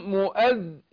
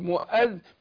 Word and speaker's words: مؤذن: فهنا اقول ايه، فأذن مؤذن، مؤذن، مؤذن، شوف التشديد، مؤذن بدون مبالغه طبعا، مؤذن بينهم، خدت بالك؟